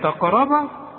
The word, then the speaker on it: تقرب: ولا ايه بقى ولا تقرب تقرب. طب صح ولا غلط؟ طبعا غلط. هو في تقرب ط ما انت قدامك ت يا راجل انت بتقولها طالي.